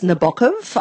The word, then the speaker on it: Nabokov: Actually, it's Nabokov. I don't know if you you've got to put the emphasis on the second syllable.